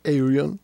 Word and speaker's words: Ayreon: Entonces en el 2000, Aireon, o su pronunciación más correcta que es Aireon.